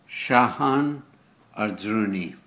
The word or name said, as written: Sahan Arzruni